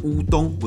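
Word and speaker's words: うどん: 哦，这个就是为什么很有趣的，就是乌龙面在日文其实是应该念的像乌冬，不是乌龙。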